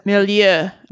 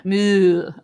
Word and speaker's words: milieu: She picks up language from, you know, around her, you know, social milieu, I guess. How do you say that? – Milieu. – Milieu. Okay.